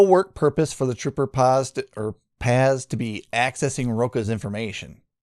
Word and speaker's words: Paz: This is a Connecticut state trooper whose name is Mitchell Paz, or Paz, P-A-Z is how it's spelled, let his girlfriend, Amanda Marino, use his laptop and then look at investigative records of her ex, who's Sean Rocha.